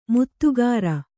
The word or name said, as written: ಮಾಧುಕರಿ